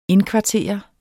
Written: indkvartere

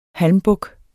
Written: halmbuk

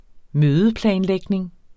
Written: mødeplanlægning

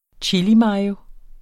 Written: chilimayo

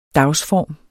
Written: dagsform

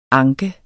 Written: anke

